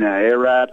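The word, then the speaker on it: Erat: Your Martin Arat, which I was just informed, that's the real way to pronounce his name, by the way.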